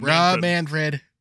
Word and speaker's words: Rob Manfred: is based on what Rob Manfred. Is that right? I don't remember if I got the name right. – Yep, Rob Manfred.